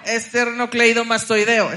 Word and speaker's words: esternocleidomastoideo: Eternocleidomastoideo. – Esternocleidomastoideo, exacto. ¡Eso! Lo dije bien.